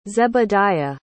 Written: zebediah

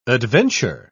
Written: adventure

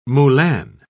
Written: moulin

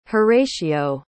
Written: horatio

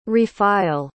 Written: refile

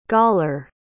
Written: goller

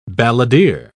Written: balladeer

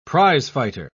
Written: prizefighter

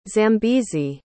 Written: Zambezi

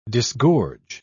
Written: disgorge

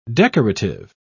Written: decorative

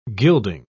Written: gilding

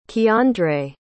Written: Keandre

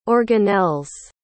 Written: organelles

